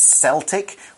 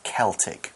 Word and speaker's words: Celtic: Careful not to say it's Celtic, which is the name of the Boston basketball team and the Scottish soccer team. But when we're talking about the culture, it's a hard C, Celtic. So the article moves on to say,